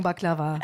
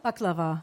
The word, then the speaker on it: Baklava: Wie sagt ihr Baklava? – Baklava. – Und wir sagen Baklava. – Baklava? – Ja, gleiches Wort, aber bulgarisch ausgesprochen. – Anders betont, ja.